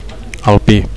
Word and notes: alpi: Catalan

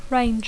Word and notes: range: English

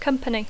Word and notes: company: English

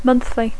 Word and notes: monthly: English

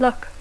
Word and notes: luck: English

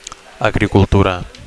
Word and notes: agricultura: Catalan

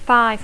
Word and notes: five: English

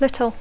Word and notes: little: English

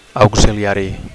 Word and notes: auxiliari: Catalan

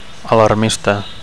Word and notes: alarmista: Catalan

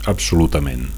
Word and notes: absolutament: Catalan

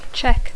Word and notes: check: English

